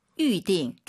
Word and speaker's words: 预定: 아, 预定. YU 발음이에요. 위, 위 하지 마시고 다시 한번. – 预定. – 둘 다 사성이에요. 검색해 보세요, 여러분. YU로 시작합니다. 预定.